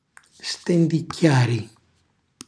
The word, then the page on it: stendicchiari: Calabrese